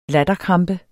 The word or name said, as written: latterkrampe